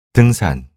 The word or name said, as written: 등산